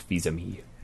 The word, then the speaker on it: Fils-Aimé: It's pronounced Fils-Aimé.